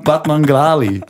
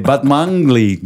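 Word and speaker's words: Batmanglij: Nein, Rostam Batmanglali. Nee, Batmangli spricht man es, glaube ich, aus.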